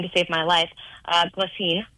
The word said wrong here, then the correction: Ghislaine: It's Ghislaine. There you go.